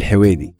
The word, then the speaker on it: Al Huwaidi: Al Huwaidi.